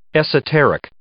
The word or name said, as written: esoteric